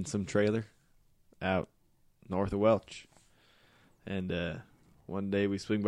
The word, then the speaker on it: Welsh: Just lives in a trailer out in the middle of nowhere, north of Welch, Louisiana. And spelled like Welsh. But, you know, everybody around there just calls it Welch. Like the grape juice.